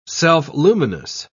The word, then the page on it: self-luminous: British English